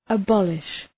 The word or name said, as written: abolish